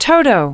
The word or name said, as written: toto